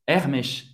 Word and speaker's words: Hermès: Hermès, em francês, Hermès.